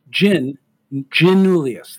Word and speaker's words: Giannoulias: I want to stress this, that when you think, and this is your mnemonic for this, when you think of Alexi, think of him holding a martini with gin in it. So you think like Alexi, you know, he's got a little bit of a James Bond look to him. Giannoulias. That's how you say his name, okay?